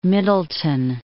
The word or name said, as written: Middleton